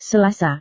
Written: selasa

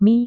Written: mie

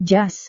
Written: jas

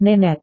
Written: nenek

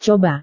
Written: coba

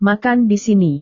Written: makan di sini

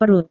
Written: perut

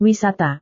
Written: wisata

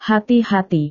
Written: hati-hati

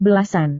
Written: belasan

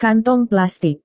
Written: kantong plastik

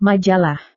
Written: majalah

